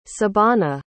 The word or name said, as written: sabana